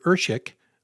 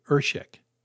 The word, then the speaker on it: Erschik: You can search my name, Richard Ershick, on Amazon. That's Richard, and my last name is spelled E-R-S-C-H-I-K, Richard Ershick.